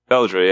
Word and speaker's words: Beldre: Beldre? – Beldre, yeah. – B-E-L-D-R-E.